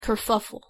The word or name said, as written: kerfuffle